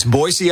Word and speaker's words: Boise: People always say it with a Z, right? – That's right. It's Boise, Idaho.